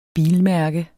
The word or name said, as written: bilmærke